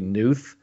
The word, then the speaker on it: Knuth: It's spelled K-N-U-T-H, so you'd think it would just be Knuth. But it's actually Knuth.